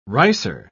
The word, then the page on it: ricer: British English